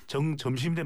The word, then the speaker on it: 정점식: 정점식 대표인데 정점식 대표 원내대표인데 이러니까 가만히 여러분들이 요즘에 보면 사진을 잘 보십시오.